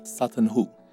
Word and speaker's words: Sutton Hoo: 那这一次我们要讲的是萨顿湖，这个英文要怎么念呢？ – Sutton Hoo。